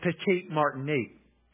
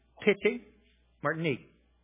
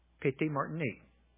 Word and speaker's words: Petite Martinique: This woman is from, we say it Petite Martinique. That's the way it sounds here, but there, the way they pronounce it is Petite Martinique. Petite Martinique. Petite, like P-I-T-T-Y, Petite. That's the way they say it.